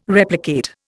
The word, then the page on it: replicate: Indian